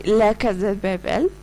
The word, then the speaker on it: La Casa de Papel: vous l'avez reconnu peut-être, c'est La Casa de Papel.